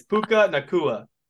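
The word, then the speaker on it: Puka Nacua: Well, hey, it's Puka Nakua. That's how I'm saying.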